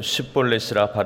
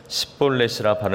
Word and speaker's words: 쉿볼렛: 그에게 이르기를 쉿볼렛이라 발음하여 에브라임 사람이 그렇게 바로 말하지 못하고 십볼렛이라 발음하면 길르앗 사람이 곧 그를 잡아서 요단강 나루턱에서 죽였더라.